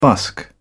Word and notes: busk: British English

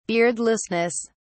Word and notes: beardlessness: British English

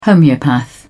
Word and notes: homeopath: British English

